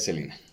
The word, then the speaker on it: Selena: Su pronunciación correcta es Selena.